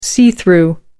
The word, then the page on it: see-through: American English